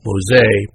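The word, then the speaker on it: Mose: Moseye, pronounced Moseye,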